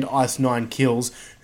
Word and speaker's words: Ice Nine Kills: Are you talking about Ice Night Kills? – Yeah. – Well, okay. Why don't you say it right now? What Luffy's talking about is the band Ice Nine Kills, who are very, what would you call them, horror.